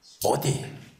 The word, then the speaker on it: bodhi: 네, 근데 보리는 여러분들 아시듯이 보디, BODHI 해가지고 산스크리트인데 한문으로 한글로는 보리라고 읽어요. 깨달음이라는 뜻입니다.